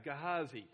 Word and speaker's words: Gehazi: Okay, Gehazi.